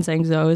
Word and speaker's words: Zhengzhou: Like it's the specifically the one in Zhengzhou is like the huge.